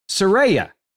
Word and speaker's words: Saraya: And now, by the way, I was vehemently defending someone whose name I was mispronouncing the whole time. So The former page in WWE has clarified her real name, and her name in now debuting in AEW is Saraya.